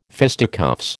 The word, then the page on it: fisticuffs: American English